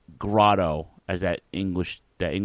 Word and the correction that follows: Grado: It's Grado.